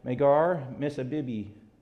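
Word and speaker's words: Magor-missabib: Then said Jeremiah unto him, The Lord hath not called thy name Pashur, but Magar-Misabibi. I said that right because I had a pronunciator do that for me.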